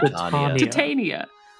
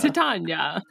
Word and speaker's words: Titania: It's like Tanya. It's like the name Tanya. – Titania. – Oh my god. Titania. Titania. Is that right? – It's a Titania. – Titania.